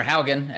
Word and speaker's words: Haugen: All right, Frances Hogan, or Haugen, as NBC called her, is probably the correct pronunciation.